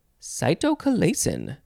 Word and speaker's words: cytochalasin: Next we have cytokalasin. cytochalasin. C-Y-T-O-C-H-A-L-A-S-I-N.